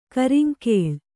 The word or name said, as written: ಕರಾಂಗುಳಿ